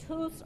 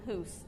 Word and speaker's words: Hus: It's Hus or Huss. It just depends on which language you're working out of. I'll go John Hus. Hus would be how he probably would have pronounced his last name.